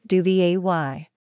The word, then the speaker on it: duvet: It is pronounced duvay.